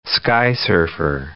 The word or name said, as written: skysurfer